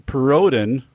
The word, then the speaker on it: Perrodin: It is Perodin, which apparently is French, although I'm not into this 23 plus me.